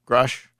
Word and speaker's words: Grusch: Grush. G-R-U-S-C-H, David.